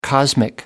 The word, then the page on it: cosmic: American English